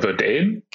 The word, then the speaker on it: Verdane: Und jetzt ist Verdan oder Verdane, ich weiß gar nicht, wie sie sich ausgesprochen, äh, wie sie ausgesprochen werden, bei euch eingestiegen. Das ist ja eine Private Equity Firma. Äh, wie kommt es, wie kommt es denn dazu? – Äh, Verdane äh, spricht man das auch.